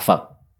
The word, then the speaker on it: Phật: Yes, it's spelled P-H-A-T. And it's Sino-Vietnamese. Phật. Yeah, it sounds like not a nice word in English language.